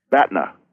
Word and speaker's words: BATNA: Batnas. – Now that that sounds like the thing that Luke Skywalker cut open and crawled inside on the ice planet Hoth. I realize. – What was it again? Batna.